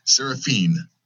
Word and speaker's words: Serefine: Serefine.